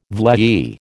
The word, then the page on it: vlei: American English